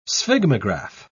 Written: sphygmograph

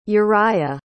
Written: urijah

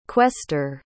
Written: quester

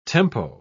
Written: tempo

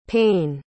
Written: paine

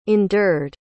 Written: endured

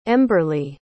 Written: emberly

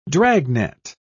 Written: dragnet

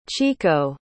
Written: chieko

